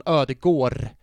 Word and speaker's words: Ødegaard: Och då sa han, faktum är på norska, nu ska jag inte härma norska för det kan jag inte, men på norska så säger man Ødegaard.